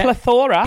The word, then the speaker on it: plethora: Plethora? Is that how you say it? – Plethora. – Plethora? – A plethora of queer talent.